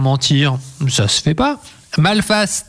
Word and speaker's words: Malphas: Ça s'appelle Malface. Ça s'écrit MALPHAS, et c'est le début d'un diptyque, c'est proposé par les éditions Ankama.